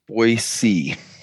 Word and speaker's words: Boise: Boise.